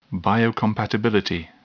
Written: biocompatibility

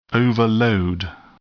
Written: overload

